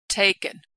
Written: taken

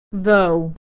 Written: though

